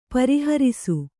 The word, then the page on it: ಪ್ರಹರ್ಷ: Kannada